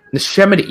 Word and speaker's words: Neshaminy: I believe it's Neshaminy. I can never pronounce. That's just one of those words. I always get like mush mouth when I try to say it. – Neshaminy.